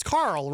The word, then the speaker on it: Carl: Kyle. – I believe it's pronounced, it's Carl, right, Glenn? Yeah, you've been saying it wrong. It's actually Carl.